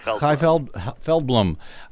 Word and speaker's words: Chai Feldblum: Is that how you say her name? – Chai Feldblum.